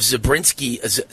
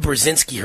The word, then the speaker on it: Brzezinski: Okay, Joe Scarborough, and his wife is the pretty blonde, and she's the daughter of Zebrinsky, I think it's pronounced, Mika Brzezinski.